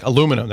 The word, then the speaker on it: aluminium: There's rumors around that there are similar sort of inventory backlogs and things like aluminium for some reason. – Aluminium? – Yeah. – Like aluminum that we say it here. – Absolutely. You Americans say aluminum.